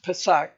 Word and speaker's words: Pesach: Because the normal word for Passover, Pesach, is it's Pesach, they call it.